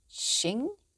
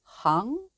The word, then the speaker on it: Xing: Now this character, Xing, it can also be pronounced as Hang, but since the theory is usually called Wuxing, to keep things simple, I'll use Xing throughout.